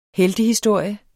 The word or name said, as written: heltehistorie